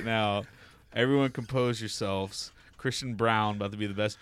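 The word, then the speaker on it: Braun: Lisa Brown, parentheses, she indicated the family's last name is pronounced Brown, said she's happy she'll have two sons playing major college basketball in the area.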